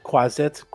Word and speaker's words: quasit: Quasit, quaist, however it's pronounced. – Quasit? – It's like quasit.